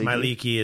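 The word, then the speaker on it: Mielikki: Did I pronounce that right? – Yes, Mileiki is perfect, actually.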